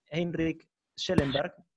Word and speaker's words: Heinrich Schellenberg: Este, queremos agradecerle muchísimo al embajador de la República de Suiza, eh, Heinrich Schellenberg, ¿lo dije bien? – Sí, Heinrich Schellenberg.